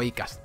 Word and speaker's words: Acast: Así que, desde este mismo programa, primero de la cuarta temporada, ya somos propiedad de Acast o Acast, ¿vale? Que es la pronunciación en inglés.